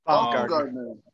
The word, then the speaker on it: Baumgartner: Baumgartner.